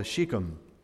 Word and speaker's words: Shechem: And I want you to notice two surprising references to an Old Testament valley called Shechem. Chapter 7, at least that's the correct pronunciation,